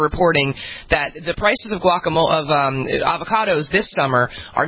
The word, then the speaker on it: guacamole: First, though, something much more important going on for those of you who are guacamole.